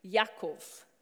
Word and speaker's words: Yaakov: So did you know that in Hebrew, James's name would have been pronounced Yaakov, which the name Jacob comes from that.